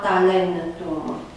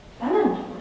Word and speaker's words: talentum: Acel A din talanton este tot vocală scurtă și când devine în latină talentum. E tot scurtă. E E scurt acolo. Da, dar silaba din care face o parte este o silabă închisă și atunci în latină vom accentua așa talentum.